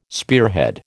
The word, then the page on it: spearhead: American English